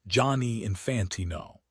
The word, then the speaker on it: Gianni Infantino: This is part of a broader Americanization of the sport under Gianni Infantino, pronounced Johnny Infantino.